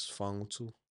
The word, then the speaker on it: Faagutu: Just always grew up hearing it as FAAGUTU.